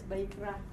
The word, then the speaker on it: Vayikra: A couple slides back, you were showing the word Vayikra. – Vayikra. – Am I saying that right? – Yes, Vayikra.